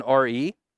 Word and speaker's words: Re: So Re, it's actually written R-E, but it's pronounced Re.